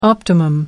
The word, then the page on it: optimum: American English